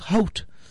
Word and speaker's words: Howth: And if you want to go even farther, take a little trip to a quaint filled fishing village called Hoat. H-O-W-T-H. It's spelled like oat. I mean, it's pronounced like oat with an H, but it's spelled H-O-W-T-H.